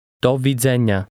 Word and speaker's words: Do widzenia: Here is how to say goodbye in Polish. Listen and repeat. – Do widzenia. Nia. Nia. Dze. Dzenia. Widzenia. Widzenia. Do. Do widzenia. – Say goodbye. – Do widzenia.